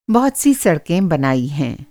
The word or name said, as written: सड़क